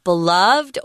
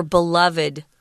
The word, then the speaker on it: beloved: This word beloved can be either pronounced beloved or beloved.